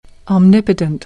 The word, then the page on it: omnipotent: American English